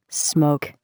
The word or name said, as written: smoke